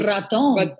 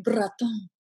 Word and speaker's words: Bratton: Bratton. – Say it that way again. – Bratton. – Oh, one more time. – Bratton.